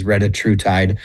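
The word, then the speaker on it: retatrutide: So we've heard it pronounced 100 different ways. The one that I like to go with is retruitide.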